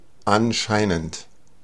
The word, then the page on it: anscheinend: /ˈanˌʃaɪnənt/